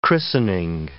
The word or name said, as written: christening